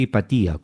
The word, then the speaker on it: Hipatia: Bueno, hay que decir que algunos pronuncian Hipatia, otros Hipatia. La pronunciación más correcta sería Hipatia, con acento en la I, si nos basamos en el original griego.